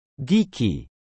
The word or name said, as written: geeky